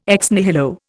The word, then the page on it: ex nihilo: Indian